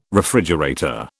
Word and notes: refrigerator: British English

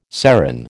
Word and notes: serin: American English

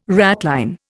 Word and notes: ratline: Indian

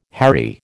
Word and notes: hairy: American English

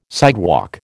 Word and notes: sidewalk: American English

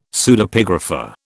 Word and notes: Pseudepigrapha: British English

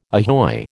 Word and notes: ahoy: American English

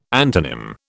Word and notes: antonym: British English

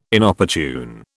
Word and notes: Inopportune: British English

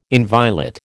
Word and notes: inviolate: American English

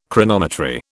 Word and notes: Chronometry: British English

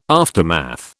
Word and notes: aftermath: British English

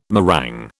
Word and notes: meringue: British English

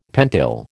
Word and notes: pentyl: American English

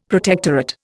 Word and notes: protectorate: Indian

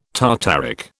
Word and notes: tartaric: British English

